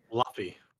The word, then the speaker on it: Luffy: He just said Floofy. – Luffy. – Oh, Luffy. No, I said Luffy.